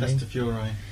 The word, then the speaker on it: Castafiore: Castafiore.